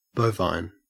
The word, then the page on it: bovine: Australian English